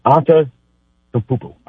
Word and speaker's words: Antetokounmpo: Obviously, there's big Giannis DeCumpo and Wimby, or how Giannis and DeCumpo, how you say his last name again? How y'all, anybody got it? Anybody got the proper? Say it again. – Giannis Antetokounmpo. I think I said it right.